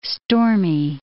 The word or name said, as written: stormy